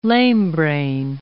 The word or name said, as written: lamebrain